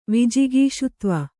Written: ವಿಜಿಗೀಷುತ್ವ

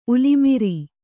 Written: ಉಲಿಮಿರಿ

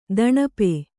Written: ದಣಪೆ

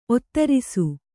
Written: ಒತ್ತಿರಿಸು

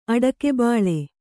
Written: ಅಡಕೆಬಾಳೆ

